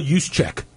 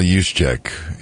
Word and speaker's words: Juszczyk: Kyle Juszczyk. – Kyle Juszczyk. Is it U-S-Z? I was going to say... – Starts with a J. – Oh, J. – Yeah. – Okay. So the J is silent. J-U-S... J-U-C-Z-A-K. – J-U-S-Z... C-Z-Y-K.